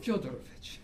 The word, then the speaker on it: Fyodorovich: Namely, although I call Alexei Fyodorovich my hero," is it Fyodorovich? – First syllable. – Okay. – Fyodorovich.